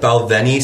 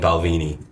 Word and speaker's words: Balvenie: I feel like Balvenie sounds a little more like prestigious. Yeah. – Yeah, because Balvenie sounds kind of like teeny, Yeah. weenie. Weenie. like we sound like Americans saying Balvini.